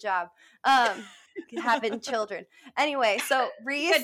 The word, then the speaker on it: Rhys: I almost pronounced it super wrong, but I recently found out that if you spell a word R-H-Y-S, that's Reese. It's not Rice.